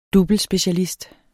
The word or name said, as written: doublespecialist